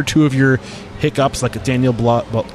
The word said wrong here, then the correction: Balud: I always say his name wrong. – Balud. Daniel Balud.